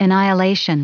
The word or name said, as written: annihilation